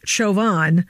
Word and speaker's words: Chauvin: Biggest story of the week is the Derek Chauvin trial, which apparently is pronounced Chauvin or Chauvin, which would have been helpful about a year ago to know that.